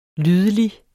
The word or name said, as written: lydelig